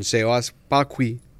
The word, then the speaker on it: Sebas Pakui: Entonces hoy estamos con Sebas Pacui, sí, Correcto.